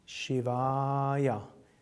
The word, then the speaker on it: Shivaya: Und dann Shivaya. Das I ist kurz, das A ist lang und das Ja ist kurz.